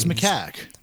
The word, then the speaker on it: macaque: No, it's macaque.